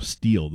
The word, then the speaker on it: Stihl: It's spelled S-T-I-H-L, Steele, though, is how you say it.